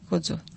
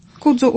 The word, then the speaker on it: Kudzu: Kudzu pisze się przez K, czyli K-U-D-Z-U. .pl. – U otwarte, tak? Kudzu. – Kudzu u otwarte.